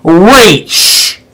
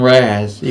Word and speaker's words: Ra's al Ghul: And it's pronounced Ra's. – People keep calling him Raz, even on the other review chain. It's not Raz.